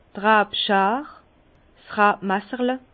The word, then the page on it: d' Rabschar: Bas-Rhin; Herrlisheim